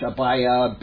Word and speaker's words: Abijah: Abiah, A-B-I-J-A-H, Abiah, Abijah, however you want to pronounce it, it's Abiah in Hebrew.